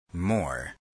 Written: more

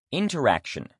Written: interaction

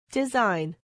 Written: design

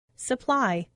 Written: supply

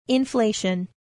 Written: inflation

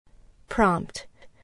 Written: prompt

